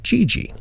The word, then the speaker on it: Gigi: And we're going to change some names here to protect the guilty and innocent, but we're going to call her Gigi. So, it rhymes with wee-wee. – Yeah, so wee-wee and Gigi.